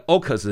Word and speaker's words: AUKUS: 那这个 AUKUS 呢是利用他们三个国家的那个字首，但是 AUKUS 的音也很近另外一个字了哈。好，这个 AUKUS 这个机制呢，他们有一个共同的决议，就是要强化这个澳大利亚呢他们的核子前舰。